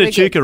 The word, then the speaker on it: Echuca: Well, you know, you said Echuca, good.